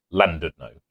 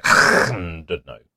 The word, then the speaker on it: Llandudno: So, for example, it is Llandudno as opposed to Llandudno because it's an L, which is pronounced L.